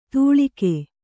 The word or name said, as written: ತೂಳಿಕೆ